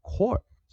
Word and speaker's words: corps: 这个单词我们是念 course，course，course，course 啊，我们念 core 这就好了，core，core，core，也就是这个单词它本身很特别，它的 P S 都不发音，我们直接念 core 就好了。 core core core，它好像我们在讲的 corporate 啊，corporate corporate 啊，集团。然后，但是我们缩写 c o r p s core 就好了，不要念 corpse 啊。